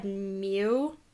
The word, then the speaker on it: miu: And I tried to look up the pronunciation of this, but it says the Egyptian word for cat is... It just said mew, but it's spelled M-I-U or M-I-U-T. – M-I-U.